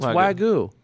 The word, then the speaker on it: Wagyu: Like you've had a piece of I don't think you pronounce the Y. Excuse me. – Wagyu? – No, it's Wagyu.